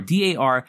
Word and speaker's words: Dar Salim: His name is Dar Salim. I've never seen him on film before. Darsalim. I hope I'm saying that right. Let me look it up. Yeah, Dar Salim.